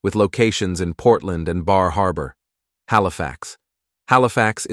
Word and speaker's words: Bar Harbor: Bar Harbor. Often pronounced Bah Haba, this is one of the most delightful stops on the cruise.